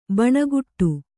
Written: ಭಣಗುಡು

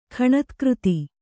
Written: ಖಣತ್ಕøತಿ

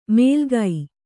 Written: ಮೇಲ್ಗೈ

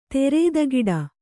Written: ಧೀರತೆಗಿಡು

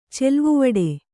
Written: ಚೆಲ್ವುವಡೆ

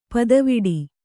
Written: ಪುದಿವೆಟು

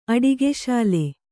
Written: ಅಡಿಗೆಸಾಲೆ

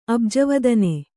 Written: ಅಬ್ಜವದನೆ

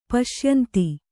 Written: ಪಶ್ಯಂತಿ

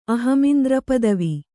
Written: ಅಹಮಿಂದ್ರಪದವಿ

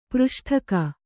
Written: ಪೃಷ್ಠಕ

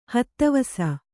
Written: ಹತ್ತವಸ

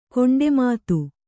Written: ಕೊಂಡೆಮಾತು

